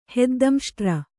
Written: ಹೆದ್ದಂಷ್ಟ್ರ

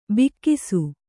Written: ಭಾಗಸ

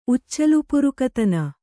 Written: ಉಚ್ಚಲುಪುರುಕತನ

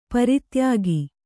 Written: ಪರಿತ್ಯಾಗ

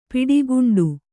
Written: ಪಿಡಿಗುಂಡು

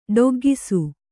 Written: ಡೊಗ್ಗಿಸು

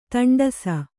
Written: ತಂಡಸು

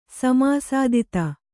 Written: ಸಮಾಸಾದಿತ

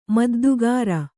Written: ಮುದ್ದುಗಾರ

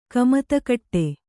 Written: ಕಮತಕಟ್ಟೆ

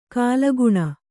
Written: ಘಲಕನೆ

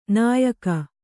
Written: ನೆಯಿಗೆ

